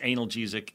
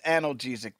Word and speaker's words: analgesic: It's pronounced analgesic. The pills go in your mouth, sir.